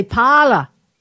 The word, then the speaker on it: Parler: We were working with a realtor from Boston several years ago, and we were looking at a house on P-A-R-L-E-R Place. And we said, how do you, he was saying it, but he'd say, "Parla. It's Parla. Parla Place."